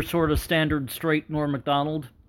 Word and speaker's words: Macdonald: Google Norm Macdonald, and it's spelled Macdonald. By the way, the D after the C is not capitalized. Norm Macdonald, I should be saying. Whenever he was on Letterman really hit the Mac in Macdonald. He made sure to pronounce it, to overpronounce it, and make it a thing.